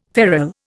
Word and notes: ferrel: Indian

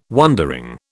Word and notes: wondering: British English

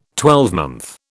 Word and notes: twelvemonth: British English

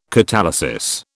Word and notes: catalysis: British English

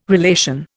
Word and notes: relation: Indian

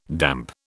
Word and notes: damp: British English

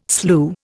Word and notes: slue: Indian

